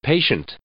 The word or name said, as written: patient